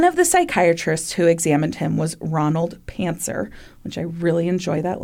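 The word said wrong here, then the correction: Pancner: Actually, I think it's Pantzner, but it's spelled with a C, P-A-N-C-N-E-R, Pantzner.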